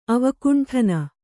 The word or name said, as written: ಅವಕುಂಠನ